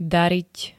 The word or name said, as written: darit